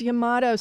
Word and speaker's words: Yamato: This Yamato, is that how that's pronounced? – Yes, Yamato. So when I was at IMG, I had the great privilege to work with the great Japanese taiko group Kodo.